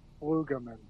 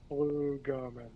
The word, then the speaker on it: Flugelman: Now, before I butcher your last name, man, before I butcher it, Flugelman. – You're close. Flugelman. – Flugelman. – Flugelman, yeah. – Flugelman. – It's German for fly man, which is awesome.